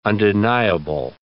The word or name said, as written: undeniable